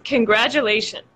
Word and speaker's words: congratulations: So there's a J sound in the middle. Congratulations. – Congratulations, yeah. – And absolutely. – Also the sh, I think.